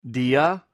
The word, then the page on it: dia: greek